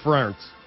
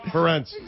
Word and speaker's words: Ferentz: Preparation has a name, and its name is Kirk Ferentz. – Oh, you butchered the name. That is great. – Ferentz. Give me the beat. Ferentz. – Ferentz.